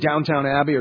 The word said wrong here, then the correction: Downton Abbey: or Downton Abbey. I didn't say that right, did I? Downton Abbey.